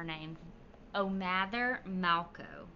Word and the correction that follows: Halco: Halco, sorry. – Halco. – O'Mather Halco.